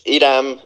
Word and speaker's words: Hiram: Yeah, so it's Hiram Villarreal.